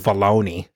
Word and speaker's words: Filoni: Filoni? – Filoni.